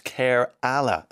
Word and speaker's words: Kerala: Someone tells me that Kerala is pronounced Kerala.